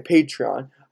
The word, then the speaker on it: Patreon: Patreon. See, that's how you say it.